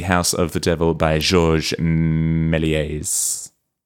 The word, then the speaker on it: Méliès: a movie by Georges Méliès from 1896. – How do we feel about that pronunciation? – What? I'm very happy with it. – Oh, okay, great.